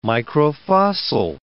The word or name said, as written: microfossil